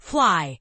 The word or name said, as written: fly